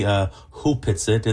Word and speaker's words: Opetsit: It's written Opetsit, but it's Opetsit. Actually pronounced properly Hupetsit.